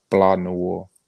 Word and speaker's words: Blanc Noir: I mean, it's called Blanc Noir, like that, and that's spelled BLANCNOIR. – Okay. Well, we'll definitely be on the lookout. – The sea is silent.